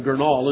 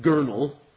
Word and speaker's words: Gurnall: Remember that I used to pronounce that William Gurnall until I heard J.I. Packer say it. And so now I believe it's William Gurnall.